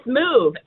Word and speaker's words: Muv: It's Move, M-U-V.